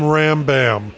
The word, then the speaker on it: Rambam: In Texas, I'm Rambam, as in thank you, ma'am.